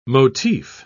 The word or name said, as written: motif